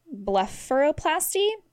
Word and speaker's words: blepharoplasty: So the top 10 most common plastic surgery procedures are liposuction, breast augmentation, eyelid reshaping surgery, which is called blepharoplasty.